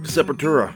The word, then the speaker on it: Sepultura: Sepultura.